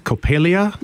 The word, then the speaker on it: Coppelia: Coppelia? – You said it absolutely correctly, yes.